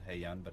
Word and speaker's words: Hye Yun: please check out Haeyeon's web series, which is also called Haeyeon, but it's spelled a little differently. Mm-hmm. And my name is H-Y-E-Y-U-N, and the show is H-E-Y. It's like Haeyeon.